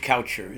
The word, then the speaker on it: Kaucher: Steven George Coucher is my full name. – Coucher? – Coucher. K-A-U-C-H-E-R. It's an old German name. – Okay. – And if I was in Germany, it would be pronounced Kaucher.